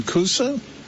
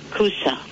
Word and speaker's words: kusa: Kusa? – Kusa, Middle Eastern summer squash. It is not zucchini. It is kusa.